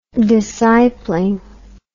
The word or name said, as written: discipling